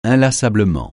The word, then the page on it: inlassablement: francais